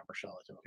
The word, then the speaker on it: Murschel: At OS Matt Mershell.